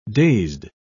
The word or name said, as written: dazed